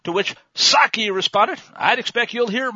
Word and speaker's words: Psaki: history, reporters began to question the White House official spokeslier, Jen Psaki. Remember, the P is silent, just like in Resident, asking whether we should expect that kind of rhetoric to continue.